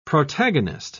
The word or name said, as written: protagonist